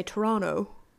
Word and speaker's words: Toronto: And the locals actually say Toronto.